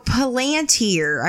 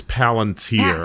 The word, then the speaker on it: Palantir: So Palantir. I'm pronouncing that correctly, right? – I think it's Palantir. – Palantir. I apologize.